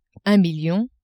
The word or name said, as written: un million